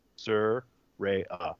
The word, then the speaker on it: Saraya: She wanted to let everybody know how to say her fucking name, and it's Saraya. That it's not Saraya. It's not Saraya. It's Saraya.